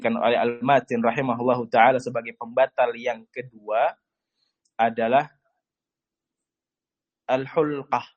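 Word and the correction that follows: al-huknah (الحقنة): al-huknah. Nah, al-huknah, afwan. Karena keliru dalam baca, al-huknah. Ini sebagai pembatal yang kedua, al-huknah fi ahadi as-sabilain. Ini pembatal yang kedua, bukan al-hulqah membacanya, al-huknah. Anda salah lihat dari nuskhah yang asliyah tadi.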